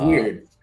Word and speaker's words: wyrd: So it's a big huge web of weird. – We spell it WYRD, but it is. It's weird, man.